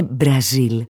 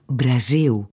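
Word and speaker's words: Brasil: Η μία και μοναδική Βραζιλιάνα φίλη που έχω μου έλεγε πάντα ότι το λάμδα στο τέλος των λέξεων προφέρεται ου. Ναι, ναι. Οι Βραζιλιάνοι που μιλάνε Πορτογαλικά δεν λένε Μπραζίλ, αλλά Μπραζίου.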